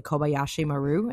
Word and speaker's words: Kobayashi Maru: So Casey, can you run us through the, please tell me if I'm pronouncing this wrong as well, but the Kobayashi Maru. Did I say that correctly? – Perfect.